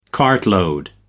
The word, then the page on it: cartload: American English